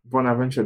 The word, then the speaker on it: Bonaventure: Bonaventure Dossou.